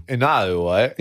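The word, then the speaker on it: Inouye: I was at the airport and it was like the departing away from Hawaii, Daniel Inoue. How do you say last name? Inoue. – No, continue. – Inoue. – Yeah.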